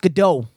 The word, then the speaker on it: Gadot: But when Jimmy Kimmel and Gal Gadot went into the theater, it's pronounced Godot.